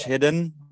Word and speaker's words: Hidde: Like the English hidden without the N. – Right. So it's like saying hidden without the end.